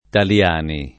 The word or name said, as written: Taliani